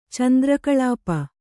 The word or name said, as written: ಚಂದ್ರಕಳಾಪ